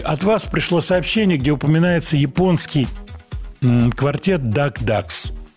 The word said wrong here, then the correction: Dark Ducks: Dark Ducks, если уж так правильно произносить.